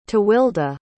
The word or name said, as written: tewelde